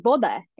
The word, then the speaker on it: woda: You know, that we say, water is woda in Polish, is how I pronounce that.